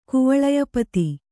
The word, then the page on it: ಕುವಲಯಪತಿ: kuvalaya pati